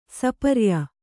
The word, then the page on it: ಸಪರ್ಯ: Kannada